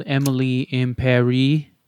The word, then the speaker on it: Emily in Paris: It's called Emily in Paris.